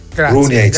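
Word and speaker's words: Runjaić: Runeich si legge così come lo dice.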